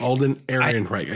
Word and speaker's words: Alden Ehrenreich: Oh, Alden Ehrenreich? – Alden Ehrenreich.